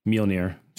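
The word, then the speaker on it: Mjölnir: Mjolnir. – Can you say that one more time? Because that was very much Mjolnir. Not the right way to say it.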